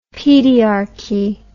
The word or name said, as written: pediarchy